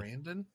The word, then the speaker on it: Sarandon: One was Chris Sanderson, Sarandon. I don't know how to say his name. He was the Sarandon. – Yeah, Sarandon.